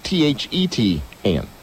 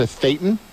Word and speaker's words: Thetan: Thetan. – Right. They pronounce it Satan.